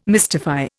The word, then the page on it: mystify: Indian